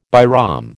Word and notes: Bairam: American English

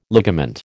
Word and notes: ligament: American English

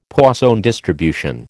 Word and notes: Poisson distribution: American English

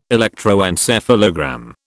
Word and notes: electroencephalogram: British English

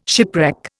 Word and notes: shipwreck: Indian

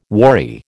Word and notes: worry: American English

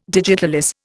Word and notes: digitalis: Indian

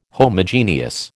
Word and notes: Homogeneous: American English

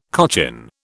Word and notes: cochin: British English

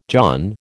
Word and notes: john: American English

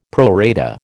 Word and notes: pro rata: American English

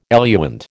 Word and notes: eluant: American English

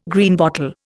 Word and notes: Greenbottle: Indian

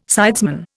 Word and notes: sidesman: Indian

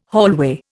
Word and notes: hallway: Indian

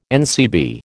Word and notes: NCB: American English

